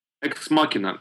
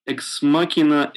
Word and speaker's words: Ex Machina: Эксмакина, вроде как правильно произносится.